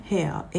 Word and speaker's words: hare: ヘア H-A-R-E これは髪の毛のヘアと全く同じです、